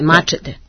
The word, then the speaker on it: mačete: Dakle, ja sam dobro akcentovala mačete. – Jeste, mačete. – Mačete.